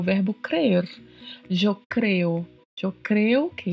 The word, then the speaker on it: creer: Esse R ao final é um R bem marcado, né? Ele é meio vibrato mesmo, tá? Crer. É importante treinar a língua para fazer a vibração deste R na pronúncia do verbo crer.